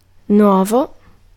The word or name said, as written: novel